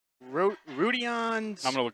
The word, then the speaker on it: Rodions: Rodions Krukus is what I had yesterday pronunciation on. – Rodions? – Yeah, okay. Rodions. – I'll trust that. That actually kind of sounds like it is spelled.